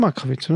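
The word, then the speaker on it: Markovics: Makrowitz, doch schon Makrowitz, oder? Nicht Malkowitz, sondern Makrowitz, glaube ich.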